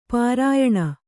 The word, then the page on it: ಪರಿಯಾಣ: pariyāṇa